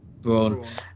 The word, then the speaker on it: Braun: Supporting actor in a drama, we have got Nicholas Brown or Braun, is it? I'm never sure how you pronounce that. Braun.